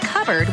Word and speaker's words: cupboard: When we come back, why it's pronounced covered when it's spelled cupboard.